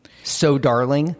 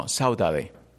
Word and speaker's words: saudade: So darling? – No, Saudade. – How do you spell that? – S-A-U-D-E-A-D-E.